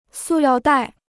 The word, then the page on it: 塑料袋: sù liào dài